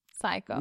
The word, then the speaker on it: psycho: And of course, like in English, we say psycho. – Yeah, the P is silent. But in Turkish, they actually say the P.